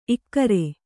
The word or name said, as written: ಇಗುರು